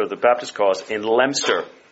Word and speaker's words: Leominster: Notice that's what I'm told is the pronunciation if you're there. It's not Leominster. One of the glories of the English language is that words aren't pronounced the way they look. And I think that's glorious, but anyway.